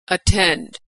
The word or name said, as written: attend